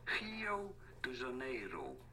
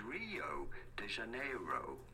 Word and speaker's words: Rio de Janeiro: It is usually pronounced as Rio de Janeiro. – De Janeiro. – De Janeiro. But in English, it is usually said as Rio de Janeiro.